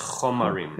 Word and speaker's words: chomarim: Are you pronouncing it chomarim or chomarim? – It's a chataf kamatz, so it's chomarim. – It is, okay. Yeah. Chomarim, chomarim. – Because the original vowel is a cholom, it's chomer, right?